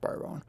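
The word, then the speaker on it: bodhrán: It's spelt Modran, but it's pronounced Boron.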